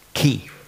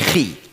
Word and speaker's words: Chi: Chi, das hat man eigentlich ausgesprochen, Ki. Nicht Schweizerdeutsch, Chi. Also nicht Chuchichastli in dieser Art, sondern eben Ki.